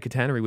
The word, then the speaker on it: catenary: Anyway, the 10-kilometer, 6.2-mile stretch of overhead catenary, which runs through a... Am I saying that right? Or did you said it right last time? – Catenary. Yeah, you're right.